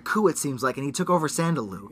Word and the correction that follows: Shadaloo: Shadaloo. You keep saying it wrong. – Is it Shadaloo? – Shadaloo. Yeah, I wrote it down. – Well, you said Sadaloo before. – I said Shandaloo, and then I corrected myself.